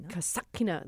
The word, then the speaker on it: Kasatkina: Kasatkina.